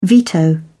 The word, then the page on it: veto: British English